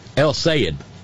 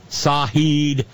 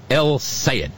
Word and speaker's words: El Sayed: Sayed is almost one word, like the word said with a long A. Or, to my ear, a very southern pronunciation of S-A-I-D. He said, like the word said with a long A, practice, he said, she said, Abdul said.